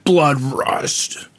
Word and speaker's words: BLOODRUST: You have to say it like that. BLOODRUST.